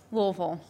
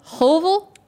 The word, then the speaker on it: Louisville: So I went to Louisville. So we hate UK. – How did you say that? – Louisville. – Louisville. – Yeah. – It's like so people who say Missouri.